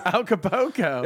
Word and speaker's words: Acapulco: Acapulco? – Acapulco.